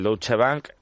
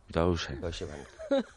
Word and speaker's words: Deutsche Bank: Y en este caso, Antonio David, él ingresaba el dinero en una cuenta del Deutsche Bank, de la Moraleja, que bien pronuncia Deutsche Bank. – Deutsche Bank.